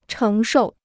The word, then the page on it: 承受: chéng shòu